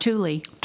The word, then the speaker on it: Thule: It's pronounced Thule.